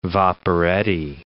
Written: vaporetti

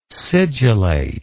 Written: sigillate

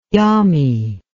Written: yami

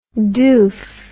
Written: doof

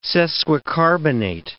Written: sesquicarbonate

